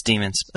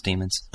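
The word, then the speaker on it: dæmons: And in the movie, I quote the article, in the movie, the organization is tearing away people's, is that demons? What is that one? – It's demons. – Okay. – It's pronounced demons.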